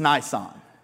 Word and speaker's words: Nisan: is called Nisan or Nisan. It's spelled like Nissan, the car. It's Nisan.